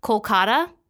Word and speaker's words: Kolkata: And so the city of Calcutta, which is what it was traditionally called, is actually now called Kolkata, spelled with a K. And that is closer to the original pronunciation of one of the first settlements that eventually became that big city. And the Calcutta is the anglicized English British version.